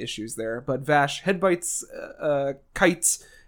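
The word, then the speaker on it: Kaite: But the flashback with Kate shows that his father wants him to be a kind of person that does things for others, but Kite, sorry, it's spelled weird. It's K-A-I-T-E, but it's pronounced Kite.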